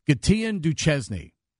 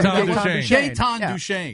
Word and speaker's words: Gaetan Duchesne: Years and years ago, I'll never forget a news person pronounced this Capitals player's name, Gatien Duchesny. – Oh. – Anybody remember Gaetan Duchesne? – Yeah.